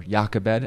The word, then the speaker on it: Jochebed: He started to governmentally enforce it, and so Yocheved, or Jochebed, as we would pronounce it,